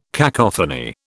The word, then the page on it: cacophony: British English